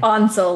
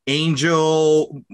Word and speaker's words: Ansel: And I've heard it pronounced so many different ways. Ansel, Ansel, Angel. – Angel. I, yeah. – There's no G in there.